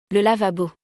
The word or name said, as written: Le lavabo